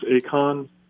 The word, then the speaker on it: Achan: I've got to the part where the walls of Jericho fall, and then the soldier, I think his name is pronounced Achan, yes.